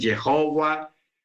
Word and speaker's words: Yahweh: Hay unos que dicen Jehová, otros dicen Yahweh o decimos Yahweh nosotros al menos.